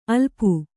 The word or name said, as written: ಅಲಪು